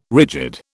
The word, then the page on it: rigid: British English